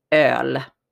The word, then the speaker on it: öl: And it's pronounced öl. So it has this O with two dots over an L.